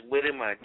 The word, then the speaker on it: Gbedema: And the last name is Widema, Gbedema. Widema. – Okay. – Fatu Widema. The G is silent.